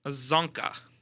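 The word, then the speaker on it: Csonka: I couldn't remember if it was C-S-Z. Or CSO. – CSZ. – Thank you for pronouncing it because I wasn't going to get that. – Yeah, it's Larry Zonka.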